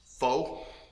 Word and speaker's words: pho: Pho. Pho. – Pho. Pho. It's pronounced pho.